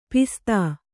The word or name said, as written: ಪಸದ